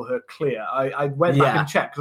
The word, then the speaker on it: Clear: It is clear. I kept saying Claire,